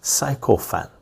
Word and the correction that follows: sycophant: Toady, leech, sponge, parasite means and un- means usually and usually obsequious flatterer or self-seeker sycophant sycophant oh is that how you pronounce it sycophant sycophant sycophant